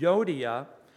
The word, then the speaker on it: Euodia: And this is what he says I entreat Euodia and I entreat Syntyche by the way, Jim, good job on pronouncing that.